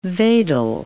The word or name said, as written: wedel